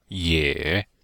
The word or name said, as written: ye3